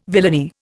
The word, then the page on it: villainy: Indian